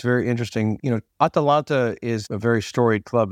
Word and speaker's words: Atalanta: Atalanta.